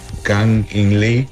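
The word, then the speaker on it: Kang-in Lee: Me gusta el jugador, se llama King. Ah, espera, a ver si me recuerdo pronunciar su nombre correcto. Se llama Kang In-Lee.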